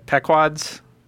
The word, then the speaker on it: Pequod's: I got pizza at Pecudo's. I don't know how to say it. Pequod's. P-E-Q-U-O-D-S. P-E-Q-U-O-D-S. – Okay.